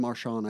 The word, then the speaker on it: Merchan: Juan Merchan, I think that's how you say it, yes.